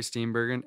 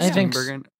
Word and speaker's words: Steenburgen: My favorite Mary Steenburgen. Am I saying her name right? – I think so. – Steenburgen.